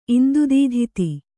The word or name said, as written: ಇಂದುದೀಧಿತಿ